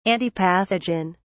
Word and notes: antipathogen: British English

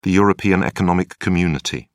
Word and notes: european economic community: British English

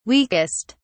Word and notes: weakest: British English

